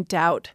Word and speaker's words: doubt: So the B was put back into the spelling, which gives us the silent B in doubt.